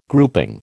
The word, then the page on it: grouping: American English